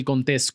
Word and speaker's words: Contés: Mi nombre es Miguel Contés con acento en la E